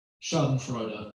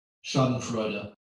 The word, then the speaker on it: Schadenfreude: Schadenfreude. Schadenfreude.